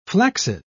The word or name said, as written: flaccid